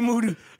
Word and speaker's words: Moody: Last name rhymes with duty. G Moody.